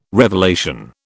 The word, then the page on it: revelation: British English